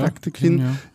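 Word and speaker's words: Dağtekin: Und Bora Dadekin, ich weiß gar nicht, wie spricht man den Dadekin, richtig? – Ja. – Dadekin,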